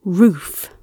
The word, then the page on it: roof: British English